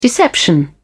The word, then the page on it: deception: British English